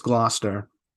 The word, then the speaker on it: Gloucester: It's Gloucester.